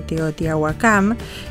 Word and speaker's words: Teotihuacán: Realmente escuchar esta particularidad tan especial de Teotihuacán, me dijeron que se pronuncia así.